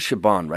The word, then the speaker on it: Siobhan: So I walk up to her and I'm like, that was for Siobhan, right?